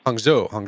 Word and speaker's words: Hangzhou: Is it Hangzhou? – Hangzhou.